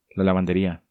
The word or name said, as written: La Lavanderia